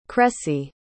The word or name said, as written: cressie